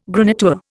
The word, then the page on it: graniteware: Indian